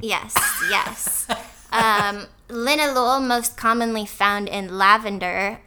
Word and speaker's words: linalool: And its name rhymes with the city where the Beatles got started. – Linalool. Like Liverpool.